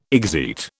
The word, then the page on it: exeat: British English